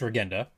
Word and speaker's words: Regenda: And by the way, I've been pronouncing his name wrong. It's not Regenda, it's Regenda. So you need guys like that.